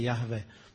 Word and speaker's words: Jahve: Jo, Jehovah, že se to píše a čte se Jahve.